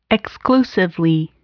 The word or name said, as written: exclusively